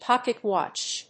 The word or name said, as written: pocket-watch